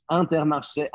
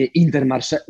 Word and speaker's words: Intermarché: wymawiamy to Leroy Merlin, a nie Leroy Merlin, ani inaczej, ani i Antermarché, Antermarché, a nie a nie Intermarché.